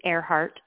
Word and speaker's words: Airhart: It's a play on words because my last name is Airhart, so it's spelled H-A-R-T.